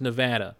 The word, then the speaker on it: Nevada: And it is pronounced Nevada, as we heard one time on the old Big Honkin' Show.